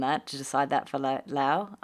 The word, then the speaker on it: Laos: you know, I've looked at Laos. I'm not saying that right, am I? Laos? – But you're saying it a lot better than Robbie would say it. – I think it's Lao, actually.